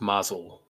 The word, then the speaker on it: Maisel: I believe it's pronounced Mazel.